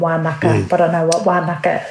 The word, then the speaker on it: Wānaka: My understanding is that, yes, it's Wānaka, which is the Ngaitahu pronunciation for Wānanga. And Ngaitahu, a lot of our NGs are pronounced as Ks.